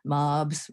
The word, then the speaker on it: Mobbs: And she would always say, Mobbs rhymes with sobs.